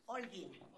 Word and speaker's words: Holguin: Yes, don't pronounce the H. Holguin.